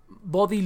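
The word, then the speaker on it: Bodhi: yo creo que las mejores tres opciones a 32 bits que encontré fue MX Linux, Body Linux, Body se escribe B-O-D-H-I, y Linux Mint 32 bits.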